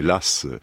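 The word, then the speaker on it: Law: Ce qu'on appelle la faillite de Lass, puisqu'il faut prononcer Lass,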